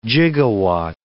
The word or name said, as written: gigawatt